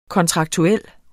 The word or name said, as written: kontraktuel